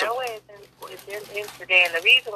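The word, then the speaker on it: Meroë: Now, when we look at, is the correct pronunciation, Meroe? – Meroe. – Okay, M-E-R-O-E, Meroe.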